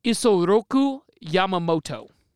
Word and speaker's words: Isoroku Yamamoto: Admiral Isoroku Yamamoto.